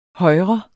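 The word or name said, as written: højre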